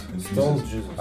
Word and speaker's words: Stoned Jesus: Что ты неправильно произносишь название? Stone Jesus. Stone Stoned Jesus.